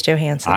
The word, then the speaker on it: Johansson: So they are filming this movie on Tybee Island, which is where we live, and they have Scarlett Johansson.